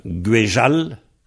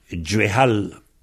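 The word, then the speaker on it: gwezhall: Disons, il y a Guéjal en KLT, Guéjal, et Djuéhal en Vanté.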